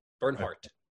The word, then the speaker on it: Bernhardt: That DT bit at the end that confuses me. – It does. It's like, do I pronounce the T or do I not? – You do. Bernhardt.